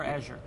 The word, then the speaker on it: Azure: Is it Azure or Azure? – It's Azure. Rhymes with Badger is what I've been telling people. – Azure. – It almost does. It doesn't quite. But close enough.